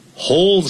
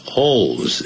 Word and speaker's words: hold: 还有一个字很棒， I hold your love Dear, hold something dear. Holds, H-O-L-D. 不要念成 hold，那个 H-O-L 那个那个尾音呢，念成欧洲的 O 啊，把它念出来 hold，舌尖在递上去。 Hold your love dear，就是珍惜。 D-E-A-R, hold something dear，一样的意思。同时很重视或珍惜。